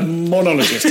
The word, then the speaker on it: monologist: Let me just correct your pronunciation so I sound less of an idiot on the show. A monologist. Yeah.